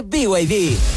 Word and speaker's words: BYD: ¿Los VIB? – ¿VIB? ¿No es BYD? – Nada que ver. Se escribe BYD, pero se dice BYD.